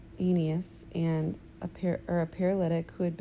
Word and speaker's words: Aeneas: How do you say this name? – Aeneas. – Aeneas.